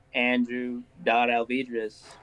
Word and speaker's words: Alvidrez: It's Andrew.Alvidrez. – Alvidrez. I didn't know how you pronounced your last name. Alvidrez with a D. – Yes, ma'am.